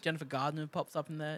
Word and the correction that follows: Garner: Not like Gardner. – I'm pretty sure you pronounce every letter in that last name. Gardner. – So what did you think I said? – Gardner. – Yeah. It's Garner.